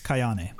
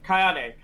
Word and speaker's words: Kayane: Uh, Kanye, right? Uh, K- Kane, how do you say her name? – Kayane. So, Kayane.